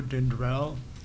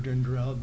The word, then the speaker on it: Dindral: Good morning, my name is Mo Dindrell. It's actually Mohinder Dindrell, but everybody calls me Mo. And our last name is spelled D-I-N-D-R-A-L.